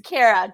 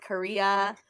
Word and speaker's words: Khaira: It's Kara, just pronounced C-A-R-A, but Kara. It's a lot better. Kara. Yeah, most people call it Korea, Kahira. Kara works perfectly for me.